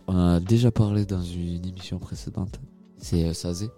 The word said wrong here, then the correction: SZA: Oh, SZA.